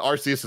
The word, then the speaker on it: Arceus: And am I saying it right, by the way? I'm not trying to do a Greg bit. Arceus? – Yes. This is the thing where no matter what way we say it, people are going to say we're saying it wrong. But Arceus is the way I say it.